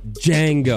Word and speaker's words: Django: It's the Quentin Django. – Tarantino. – I think it's just Django. – You think it's just Django? Well, what's with the D? – Django.